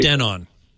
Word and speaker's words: Denon: Denon.